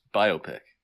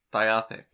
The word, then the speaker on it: biopic: Biopic. – Definitely not biopic. – It's definitely biopic. Which, I posit, which one is more fun to say? – Biopic. – That's right. So, ergo. – Rhymes with myopic.